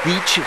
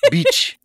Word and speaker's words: Bitche: Yes, it is the town of Beach, spelled B-I-T-C-H-E. Beach.